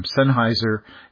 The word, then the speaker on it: Sennheiser: Sennheiser.